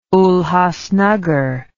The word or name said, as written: Ulhasnagar